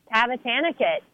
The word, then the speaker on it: Tavatanakit: Did I say her name right? – Close. Tavatanakit.